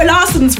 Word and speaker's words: Larsson: And he was like, Larsson. And I was like, okay. – He didn't say it like that, but he was like, it's Larsson. And I was like, okay, Zara Larsson.